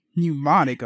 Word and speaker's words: Mnemonic: Mnemonic. Okay. Johnny Mnemonic. – The M is silent.